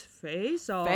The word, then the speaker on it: Face/Off: It's pronounced face/off. – It's a silent slash.